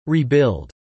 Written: rebuild

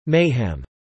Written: mayhem

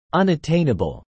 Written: unattainable

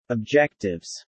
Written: objectives

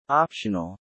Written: optional

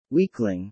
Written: weakling

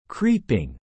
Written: creeping

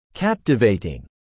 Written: captivating

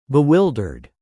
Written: bewildered

